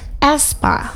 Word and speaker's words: aespa: Iya, jadi sebenarnya aespa ini adalah salah satu grup apa gimana gue sebutnya? – Aespa. – Aespa. – Iya, bukan aespa tapi aespa.